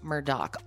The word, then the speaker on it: Murdaugh: So the Murdaugh family, their name looks like Murdaugh. It's pronounced Murdaugh.